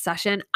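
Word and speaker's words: xies_ion: Uh, my Instagram handle is X I E S underscore I O N my podcast, if you guys haven't heard, is pronounced session. I know it doesn't look like session, but it is.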